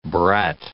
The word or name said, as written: brat